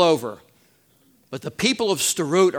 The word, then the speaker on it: Sderot: Sderot. S-D-E-R-O-T, pronounced Sderot.